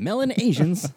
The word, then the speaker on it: Melanesians: Damn, Melanesians. – Fucking Melanesians, man. God damn it. – Not melon. – Oh, Mela. Well, oh, yeah. I put the emphasis on the wrong syllable.